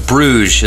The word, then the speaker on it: Bruges: Bruges is what it's called. So yeah, In Bruges, right? – The S isn't pronounced.